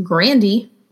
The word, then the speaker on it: Grande: So since uh, since my last episode with my friend Matt, where we were talking about Ariana Grande, which by the way is pronounced Grandy.